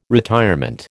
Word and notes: retirement: American English

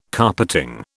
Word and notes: carpeting: British English

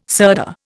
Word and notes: sirdar: Indian

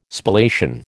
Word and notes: Spallation: American English

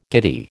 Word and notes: giddy: American English

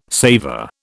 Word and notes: saver: British English

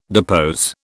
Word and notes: depose: British English

